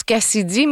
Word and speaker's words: KCIDY: Parlons maintenant de l'artiste Cassidy, qui se prononce Cassidy, mais ça s'écrit K-C-I-D-Y.